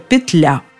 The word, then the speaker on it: петля: Однако, повторю, если вы хотите услышать однозначный ответ, как же все-таки правильно, отвечаю – петля.